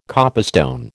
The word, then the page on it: copestone: American English